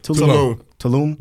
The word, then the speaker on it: Tulum: Tulum.